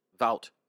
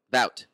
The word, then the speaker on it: Wout: How do you pronounce his first name? – Vout. – Vout. Okay, so it starts with a W.